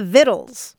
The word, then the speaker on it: vittles: vittles. he said she was born in 1898 and grew up in Glasgow, Scotland. – Okay. – Yeah, it's spelled like vitals, but it's pronounced vittles. Swabbing the vittles.